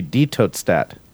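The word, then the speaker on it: Die Tote Stadt: Since it's German, die is pronounced D. – So it should be Die Tote Stat.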